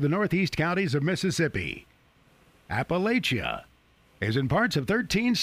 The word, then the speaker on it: Appalachia: And that is the correct pronunciation, Weed. It's not Appalachia. Or Appalachia. It's Appalachia.